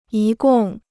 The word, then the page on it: 一共: yī gòng